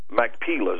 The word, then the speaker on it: Machpelah: Machpelah is how you pronounce it.